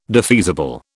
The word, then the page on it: defeasible: British English